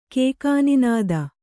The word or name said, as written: ಕೇಕಾನಿನಾದ